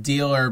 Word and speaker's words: Dehler: His last name is pronounced Dealer, but it's spelled D-E-H-L-E-R.